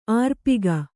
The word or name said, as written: ಆರ್ಪಿಗ